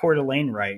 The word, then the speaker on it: Coeur d'Alene: There's been this trend through all these episodes where I mispronounce city names, but I got Coeur d'Alene right? – Yeah, man.